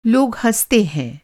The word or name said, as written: हँसना